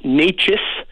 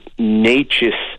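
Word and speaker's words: Nečas: Mais je crois que ça s'appelle N-E-C-A-S ou C-A-S. – Ouais. – Puis j'ai dit, regarde, j'ai dit N-E-C-A-S. J'ai dit, comment tu prononces ça? Puis elle me le dit, puis j'ai dit, non. Non, non, non. C'est Natchez.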